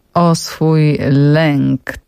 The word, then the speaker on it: Oswój Lęk: O swój lęk To jest hasło przewodnie, też specjalnie tak to wymawiam, ponieważ forma graficzna do tego skłania.